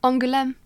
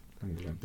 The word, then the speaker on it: Angoulême: Angoulême. – Angoulême, there yeah.